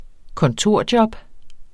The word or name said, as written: kontorjob